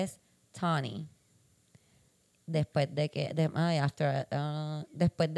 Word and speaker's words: Tawni: Eh, porque el nombre se escribe T-A-W-N-I, ah, pero se pronuncia Tony.